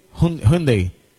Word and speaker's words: Hyundai: ¿Lo dije bien? Hyundai. Mira para allá. La forma correcta.